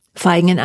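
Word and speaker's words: Vaihingen: Äh, Verhingen? – Feigen an der Enz.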